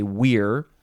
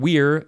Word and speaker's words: vir: plus vir, although they would say weir. So their pronunciation of the whole word would be duumvir. And the word weir, V-I-R, means man.